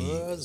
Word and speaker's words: Mersey: Äh, also Mercy, meine Güte, ich, ich kann es nicht richtig aussprechen. Mercy. – Mercy. so muss man sagen.